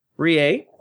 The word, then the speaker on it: Reay: Rie. – Say that again. – R-E-A-Y. That's how you would pronounce it.